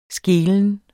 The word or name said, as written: skelen